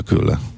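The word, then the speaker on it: kyllä: A betű umlauttal a vége, K, Y, L, L, A. – Hát ez a külle.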